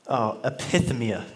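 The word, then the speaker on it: epithemia (ἐπιθυμία): And when you study this word in the Greek, this word desires, it's pronounced epithemia. That's how you say it.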